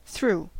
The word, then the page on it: über: /ˈyː.bɐ/; /ˈʏbɐ/